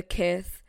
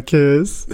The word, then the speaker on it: Kith: No, seriously, I'm manifesting a dude to buy me the Kith flower bouquet. Kith is this brand that I really like. Why is you laughing? – Because I thought you were mispronouncing kiss. – No, it's kith.